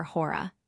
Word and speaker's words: Hora: Or rather, as they now call her Hora, with the first syllable lengthened,